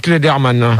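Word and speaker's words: Clayderman: Non, Richard Kleptoman. – Ah ouais. – Richard Klederman. – Ah oui, voilà, Richard Klederman, voilà.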